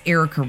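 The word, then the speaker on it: Ericka: For me, it's Erica Reddick. That's E-R-I-C-K-A-R-E-D-I-C.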